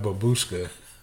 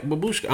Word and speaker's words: babushka: This nigga finna be a human babushka. – That's how you say it? – Yes. – Okay, babushka.